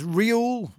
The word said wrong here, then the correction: Ruel: Walters? – Ruel. – Close, not even close. To be honest, I got it the wrong way around. Ruel Walters.